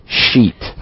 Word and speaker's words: sheet: You've got to be very careful with the pronunciation, right? – Yeah. – Got to be sheet. – Yes, there's a bad word that sounds very similar to sheet.